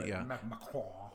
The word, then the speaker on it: Macron: Macron, is that how you say it? – Yeah, I don't try to talk like the people in it. – Macron.